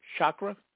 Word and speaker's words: Chaga: For example, and I may not pronounce this, is it a chakra tincture? A few drops of some elixir. – Chaga. – Chakra? – Mm-hmm. – Is that right? – Yeah. – What Chaga.